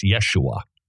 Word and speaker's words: Yeshua: By the incidentally, Jesus' name wasn't pronounced Jesus. It was Yeshua. That's how you pronounced his name.